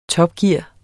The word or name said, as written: topgear